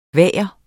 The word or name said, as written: VAGERNES